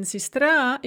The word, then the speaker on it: сестра: The same with сестра, сестры, in which the yo in сестры, once the stress is taken away from it, in сестра, it becomes a ye, and that ye is pronounced as an e, сестра.